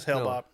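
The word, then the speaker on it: Hale-Bopp: and they come into contact with Hale Bop or Hale, whatever the hell it's called. I say that right? – Yeah. – No, it's Haley's Comet, right? – It's Hale Bop. Yeah.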